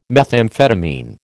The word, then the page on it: methamphetamine: American English